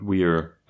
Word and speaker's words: Weir: Genau, von Andy Weir oder Meyer. Eins von beidem. – Weir. Also ich glaube, man spricht es Weir aus. Auf jeden Fall schreibt man es W-E-I-R.